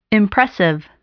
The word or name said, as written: impressive